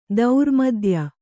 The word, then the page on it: ಧರ್ಮಾದಾಯ: dharmādāya